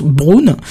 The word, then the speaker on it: Brno: Alors ça s'écrit B-R-N-O, mais apparemment Apparemment, ça se prononce Brune.